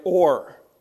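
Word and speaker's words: o'er: Some poets will say, well, I can't write over, so I'm going to write or, right? O apostrophe E-R.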